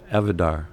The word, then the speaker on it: Avodire: Is it Avodire? Is that how you say it? – Avodar. – Avodar.